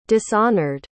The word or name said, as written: dishonored